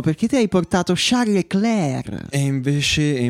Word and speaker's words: Leclerc: Perché, perché il, il, suono duro finale non si pronuncia di No, è Leclerc.